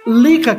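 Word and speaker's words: Lieke: Lica, que se escreve Liek, Douglas.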